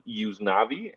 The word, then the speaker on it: Yusnavy: There's a lot of Cuban-Americans walking around with the name Yuznavi, and it's spelled Y-U-S-N-A-V-Y.